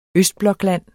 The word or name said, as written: østblokland